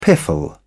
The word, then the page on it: piffle: British English